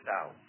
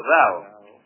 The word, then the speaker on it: Tao: Y la que hoy se usa más es X-I-A. Y así todo. Es más segura la actual. Es más verdadera. Por ejemplo, Tao, en realidad no se dice Tao, sino Dao. Y de acuerdo a la antigua grafía se escribía Tao y todo el mundo decía Tao.